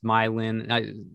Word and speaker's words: Meilin: Like the four central characters, this group of friends, Mylin, is it, I think I'm reading that it's pronounced Mylin.